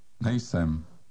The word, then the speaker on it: Nejsem: Nejsem. Nejsem. – It's one word only. The stress is on the first syllable.